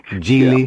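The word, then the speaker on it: Gigli: Is that how you pronounce it formally? Gili? – Gili.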